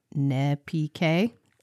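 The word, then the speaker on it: Nezpique: Bayou Nez Peak, pronounced Ne-P-K, is a small river located in the Mermentau River basin of South Louisiana.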